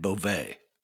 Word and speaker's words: Bove: Well, it's been pronounced two ways, Bovey and Beauvais. So either one of those are correct, but Bove, no.